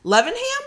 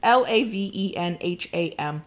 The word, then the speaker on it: Lavenham: Oh, I'm doing this wrong. Levenham? Levenham? My UK friends, tell me how to say this. It's Lavenham.